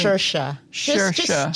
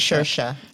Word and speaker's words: Saoirse: Saoirse. Just remember it's Saoirse. Don't look at the spelling.